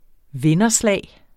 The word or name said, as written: vinderslag